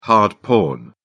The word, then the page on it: hard porn: British English